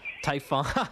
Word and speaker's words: Typhon: And the other thing is, I'm not Greek, so I'm only assuming that it's pronounced Typhon, but who knows?